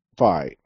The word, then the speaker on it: Fi: And I know it's Project Fi because one of the engineers says that it rhymes with Hi. So Project Fi, F-I.